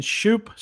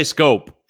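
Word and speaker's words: Schoop: Batting fifth for the Tigers is Jonathan Shoop. Scope. I don't know how to say his name. Never will. What is it? What is it, Mike? – Scope. – I say Scope. It's Scope. Don't say Shoop.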